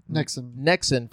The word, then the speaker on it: Nexon: Nexon. I would pronounce it Nexon.